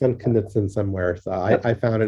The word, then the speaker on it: Knudsen: We do pronounce the K. Not everybody who's a Knutson does. It's a Scandinavian like Smith, you know, it's Knutson. K-N-U-D-S-E-N.